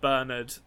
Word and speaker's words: Bernard: We get confirmation that it's pronounced Bernard.